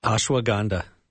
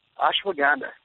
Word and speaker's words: Ashwagandha: or how do you say that? – Ashwagandha. – Ashwagandha. – Okay. There it is.